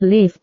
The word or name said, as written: lift